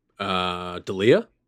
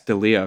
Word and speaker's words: D'Elia: Chris de what? – D'Elia? – Yes, D'Elia. Okay, I was going to say Delia or something like that.